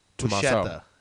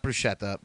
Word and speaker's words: Buscetta: I think it's actually pronounced Buscetta. It's Tommaso Buscetta. Mm-hmm. That's what I said. What'd I say? – French. – Bruschetta? – No, not Bruschetta. Buscetta.